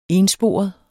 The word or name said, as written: ENSPOREDE